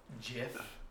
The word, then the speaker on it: GIF: the creator came out and was like, "It's pronounced GIF," and I'm like, "Like the peanut butter."